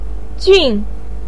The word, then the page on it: jun: 4